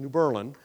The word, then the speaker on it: New Berlin: There's a little town in central Pennsylvania named New Berlin, although they don't pronounce it that way. It's pronounced New Berlin, just so nobody gets too confused about the Germans after the war, right?